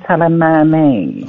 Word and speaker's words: Soleimani: Now, we got some latest developments coming up over the tragic death of, and as this is the Iranian pronunciation, Qasem Soleimani.